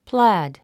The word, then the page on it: plaid: plǽd; プラァド